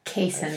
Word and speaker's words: Kayson: Kayson. – I feel like you rhymes knew him. – With, rhymes with Jason.